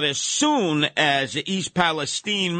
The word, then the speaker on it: Palestine: Yeah, well, you'll never be able to get back to living your lives the way they were in East Palestine. That's the way I would pronounce it instead of Palestine.